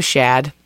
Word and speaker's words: Shad: His name is Shad. – Is it spelled like Chad? – Or but with an S.